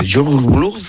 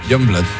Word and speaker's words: Youngblood: ¿Cómo se pronuncia? – Youngblood.